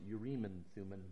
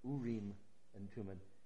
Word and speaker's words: Urim and Thummim: I know that some people pronounce it Urim and Thummim, but it's Urim and Thummim.